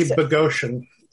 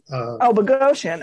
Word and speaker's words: Boghossian: Peter Boghossian, who is, I believe, a philosophy professor at, is it Portland State? Right, I think so. Yeah. – It's apparently Boghossian. – Boghossian.